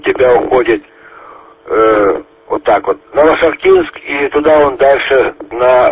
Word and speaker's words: Новошахтинск: А у вас Новошахтинск. Это 100 километров от Ростова-на-Дону. Новошахтинск. Новошахтинск. Вернее, так, ударение сделать так будет вернее.